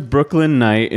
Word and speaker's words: Brooklyn Knight: It's spelled a really, really confusing way where they put the silent K in the middle and it just doesn't read at all. – Brooklyn Knight. – Brook Click Knight, because the K comes before the N.